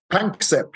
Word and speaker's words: Panksepp: And of course, heavily influenced by your friend and colleague Jack Pransep as well. Do I say his name right there? – Panksepp, yes.